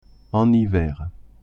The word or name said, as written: en hiver